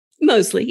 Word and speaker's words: Mosley: And now that I say Mosley, is it a long O? – Mosley. Yep. Okay. You got it.